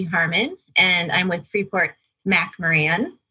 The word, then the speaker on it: McMoRan: It just looks a little different than it sounds, but it is McMoRan. The Mac, Mo, and Ran are for three people who were the founders of the company.